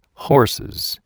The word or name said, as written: horses